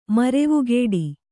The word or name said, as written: ಮರವೆಗೂಡು